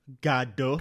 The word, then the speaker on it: Godot: Um, I saw him in uh, I think Waiting for Godot or Godot. Godot, I think they pronounced in that production.